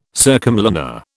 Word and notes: circumlunar: British English

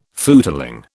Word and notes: footling: British English

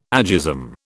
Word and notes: agism: British English

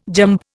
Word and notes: jamb: Indian

